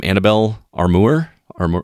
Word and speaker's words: Annabelle Armour: And also, I want to mention, I don't know how to pronounce her name, Annabelle Armour? Am I saying that right? – Yes.